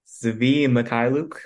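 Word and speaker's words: Svi Mykhailiuk: Zvi Mykhailiuk, I believe I said that correctly. That's how I heard it. That's how I remember hearing it, at least, which was around 4 years ago.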